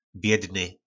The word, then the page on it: bjydny: po nasimu